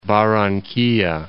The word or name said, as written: Barranquilla